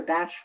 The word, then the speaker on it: Bach: And I learned that from another holistic vet about the Bach flowers or Bach flowers, which is the correct pronunciation, and have used those ever since.